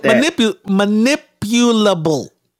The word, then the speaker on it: manipulable: Oh, I think I said it right. Manipulable. I think I said it right the first time. Capable of being manipulated. Manipulable. Oh, I said that.